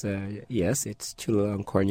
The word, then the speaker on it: Chulalongkorn: Focus on the Global South is based at Chulalongkorn University in Bangkok. Am I getting anywhere near the correct pronunciation? – Oh, yes. It's Chulalongkorn University.